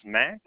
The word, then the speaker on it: Max: My first name is Max, spelled M-A-X. Last name is Sprague. That's spelled S-P-R-A-G-U-E.